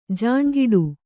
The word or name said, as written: ಜಾಣ್ಗೆಡು